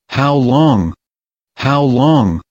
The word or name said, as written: how long